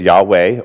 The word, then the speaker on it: Yahweh: Sometimes in English, it's pronounced as Yahweh or Jehovah.